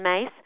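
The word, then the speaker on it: Myss: I highly recommend people listen to Carolyn Mace, surname's M-Y-S-S, but she pronounces it Mace, American.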